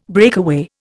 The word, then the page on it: breakaway: Indian